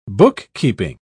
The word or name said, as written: bookkeeping